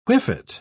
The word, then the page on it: whiffet: British English